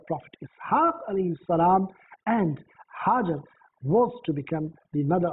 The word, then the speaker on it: Hajar: It is also pronounced as Hajira, but actually the pronunciation which is found in books is Hajar.